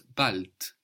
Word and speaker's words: bald: For example, Kurs in German is Kurs, K-U-R-S. – Kurs. – The word for soon was bald, bald. – Bald. – Bald is spelled B-A-L-D. A D on the end of the syllable in German is pronounced more like a T. So B-A-L-D, but what we hear is bald.